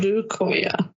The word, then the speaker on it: Odukoya: Odukoya. Okay. – Yeah, yeah, yeah, yeah. Yeah, good pronunciation. I like, yeah, Odu, yeah, Odukoya. Yeah that that is it.